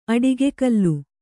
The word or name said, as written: ಅಡಿಗೆಕಲ್ಲು